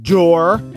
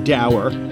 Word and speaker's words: dour: And on a day when Donald Trump called Mitch McConnell dour, or depending on your pronunciation, dour, not only that, but unsmiling.